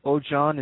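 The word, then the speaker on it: Oshun: I don't know if you ever heard of the energy of Onshan, or it's pronounced Ojan.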